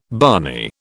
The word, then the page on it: barney: British English